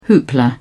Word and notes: hoopla: British English

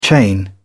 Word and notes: chain: British English

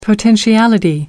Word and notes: potentiality: American English

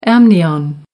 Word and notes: amnion: British English

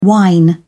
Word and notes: wine: British English

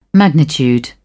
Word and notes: magnitude: British English

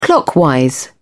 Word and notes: clockwise: British English

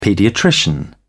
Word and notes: paediatrician: British English

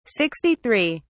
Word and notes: 63: British English

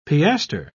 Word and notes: piaster: British English

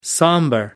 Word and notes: somber: British English

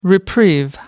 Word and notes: reprieve: American English

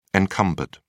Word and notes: encumbered: American English

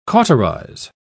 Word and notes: cauterise: British English

